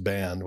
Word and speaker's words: bean: The first one is ban, B-E-A-N, but pronounced ban, which means woman.